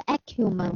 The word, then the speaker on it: acumen: 嗯，那么这个 acumen，我们看一下它的拼写，a c u m e n。我们再来一遍，a c 啊，这个后面是接的是 u。然后呢，一个 m，m 是两个门的那个 m 哈，m e n 啊，它是 acumen，